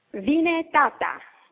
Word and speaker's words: Vine Tata: And the play is called, Vine Tata? Is that how we pronounce it? – Vine Tata. – Vine Tata. – Vine Tata. and I think you pronounced it very well.